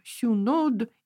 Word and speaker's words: синод: Ну, например, слово «синод» да, или слово «миро», вот то масло, которым помазывают да, на царство или при крещении, они звучали как «сюнод» и «мюро». Но ну, я так немножко утрированно это произношу, но все-таки в церковном произношении эти слова звучали иначе.